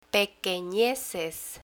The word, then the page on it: pequeneces: Spanish